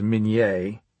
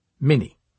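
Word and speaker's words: Minié: Though the correct pronunciation of the inventor's name was Minier, Americans insisted on pronouncing it without the acute accent, Minnie, which made this brutally destructive invention sound like a child's toy.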